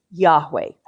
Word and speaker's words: Yahweh: And more often now, you will hear English-speaking people pronouncing this word Yahweh.